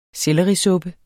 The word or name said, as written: sellerisuppe